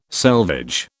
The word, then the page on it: selvage: British English